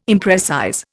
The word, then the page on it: imprecise: Indian